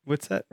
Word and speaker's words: Whitsett: Whitsett? – Yeah, you're spot on.